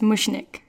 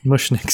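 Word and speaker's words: Mushnik: Yeah, it's pronounced Mushnick. – Mushnick, sorry.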